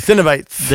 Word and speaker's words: Cenobites: Am I saying that right? Jeez. I got to slow that down. – Cenobites. – The Cenobites.